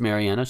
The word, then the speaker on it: Marianna: And yeah, it's pronounced Marianna. She keeps pronouncing it wrong.